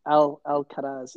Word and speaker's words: Alcaraz: I think it's Alcaraz, yeah.